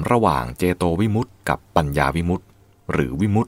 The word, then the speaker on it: วิมุตติ: วิมุตติที่เป็นภาวะและอาการสะกดดังนี้คือวอแหวนสระอิมอม้าสระอุตอเต่าและตอเต่าสระอิซึ่งอ่านว่าวิมุตติหรือถ้ามีคำมาต่อท้ายก็จะออกเสียงเป็นวิมุตติเช่นวิมุตติญาณทัสสนะวิมุตติสุขเป็นต้น